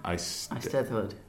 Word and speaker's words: Eisteddfod: Eisteddfod.